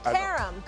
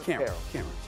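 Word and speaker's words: Qerim: Carum. Good job. It rhymes with harem. Carum. – Carum.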